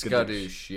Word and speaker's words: Skadoosh: I like how you said Chris Bloosh or Kadoosh. Is it Skadoosh? – It's Skadoosh, yeah. – You almost said it right, and then you changed it wildly because you said Skading, which is not it.